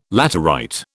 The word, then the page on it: laterite: British English